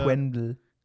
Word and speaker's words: Quenville: Wie, wie habe ich den, wie, wie, spricht man die Nummer aus? Von, von Quen, Peter Quenville. – Quen, Quen, Quenville.